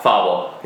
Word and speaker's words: Fauble: Fauble. Yeah. – It's all right. Many years of French, and like you always want to go for the French pronunciation.